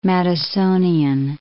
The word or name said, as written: Madisonian